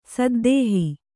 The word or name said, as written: ಸದ್ದೇಹಿ